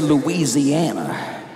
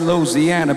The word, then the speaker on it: Louisiana: Now, Louisiana, you notice I say it right? I don't say Louisiana. I say Louisiana, baby.